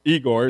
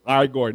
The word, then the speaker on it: Igor: It's not pronounced Igor. It's Igor.